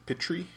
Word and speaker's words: Pitry: P-I-T-R-Y? – Yes. – Okay. – Yeah, I pronounce it Pitri. – Pitri? I'm thinking it might be Pitri because of the Russian influence.